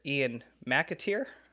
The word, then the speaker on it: Ian McAteer: I Mr. Ian McAteer. – That's it. You got it.